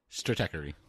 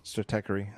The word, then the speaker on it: Stratechery: Stratechery. – Stratechery.